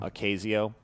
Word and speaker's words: Ocasio: Ocasio? How would you say that? – Ocasio. – Ocasio. – Okay.